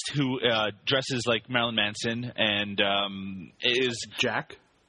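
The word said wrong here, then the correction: Jak: Well, I'm pretty sure it's pronounced Jake, even though it's it's spelled J A K.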